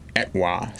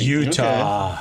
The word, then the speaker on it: Etowah: on Etowah Street. – Utah. – Okay. Etowah. – It's Utah Street. – I'm going Etowah. – Utah Street. – That's not spelled like Utah.